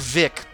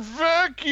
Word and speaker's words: Veeck: No. Not Mike Vic, but Mike Vec. – Yeah. – Like V-E-E-C-K. – Mike Veek! – Yeah, I think it's pronounced Vec. All the news videos that I saw, they actually pronounce his name Vec, as far as I'm...